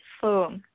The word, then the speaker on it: Phuong: Yeah, it's a Zenny, Z-E-N-N-Y. – Okay. And last name? – It's P-H-U-O-N-G, Fung.